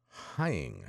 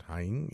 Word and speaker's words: Hying: Uh, Bishop Donald Hying, I believe that's how you pronounce it, Hying, H Y I N G, of Madison, Wisconsin, uh, has spoken out um, against the destruction of statues, specifically religious statues.